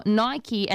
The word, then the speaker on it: Nike: And they've written it out phonetically. So Nike as in N-I-K-E. Or Nike, and they've added a Y on the end as in Nye and Key. And he circled Nike.